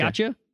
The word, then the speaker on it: Yautja: I always thought it was okay. Yautja, but it's Yautja.